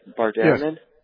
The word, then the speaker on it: Bart Ehrman: I had a couple of quick questions, but I first wanted to say on your Bart Ehrman, that's how you say it, right? Bart Ehrman? – Yes. Mm-hmm.